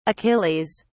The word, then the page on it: achilles: British English